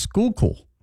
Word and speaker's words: Schuylkill: We had a discussion about Schuylkill this morning and found out it's Schuylkill. Because people from there are from the Skook, and then it's pronounced cool at the end. So Schuylkill.